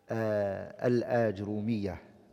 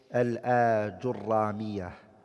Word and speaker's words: Ajrumiyyah: Today inshallah ta'ala we're going to start the explanation of the book Al-Ajrumiyyah, and some scholars they call it Al-Ajrumiyyah. Both ways is correct to pronounce it.